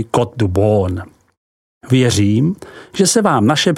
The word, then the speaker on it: Beaune: Côte de Beaune, vyslovko du Bon, se rozkládá od hranic obce Echevrone, vyslov Echevron, po Chagny, vyslovuj Šaní.